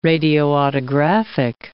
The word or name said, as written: radioautographic